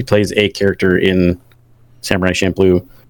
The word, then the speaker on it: Champloo: So, according to Wikipedia, it's pronounced Samurai Champloo.